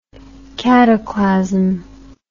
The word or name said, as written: cataclasm